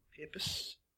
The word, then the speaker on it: papacy: It happened 600 odd years ago, but it happened because there were multiple popes vying for the papacy. Is that how you say it? – Papacy? – Whatever. – Papacy, yeah.